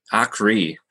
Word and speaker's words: Aakre: Aakre.